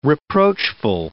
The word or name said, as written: reproachful